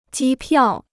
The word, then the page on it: 机票: jī piào